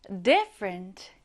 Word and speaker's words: different: Different. Different. 강세는요, 이렇게 앞에 두시면 됩니다. 앞을 강하게 발음해 볼까요?